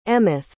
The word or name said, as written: emeth